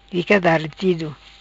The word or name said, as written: Fica Daretido